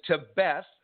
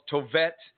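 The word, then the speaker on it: Tevet: And the 10th month in scripture is Tebet, is how you say it, the Tebet, or some call it Tovet.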